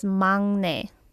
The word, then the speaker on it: 막내: So in the first sentence, there is 막내. Like, that's how it is written. – 막내. – Yes, but it has to be pronounced as 망내. – 망내. – Yeah, the 응 sound.